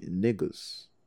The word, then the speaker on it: Negus: And they explained, there was a girl in the audience who was Ethiopian, and she told me the proper pronunciation would be Negus. But when I saw the word N-E-G-U-S,